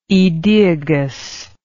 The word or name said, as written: aedoeagus